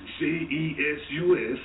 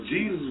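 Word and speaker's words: Jesús: You mean to tell me all these Mexicans walking around here whose name is J E S U S, Jesus, Jesus, is pronounced with a H See, they're real tricky, real crafty with their words, Dad.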